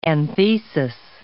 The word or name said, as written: anthesis